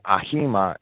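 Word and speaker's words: ahima (αἷμα): It's actually ahima in Strong's pronunciation guide. Some people might want to call it hema.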